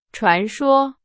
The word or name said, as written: 传说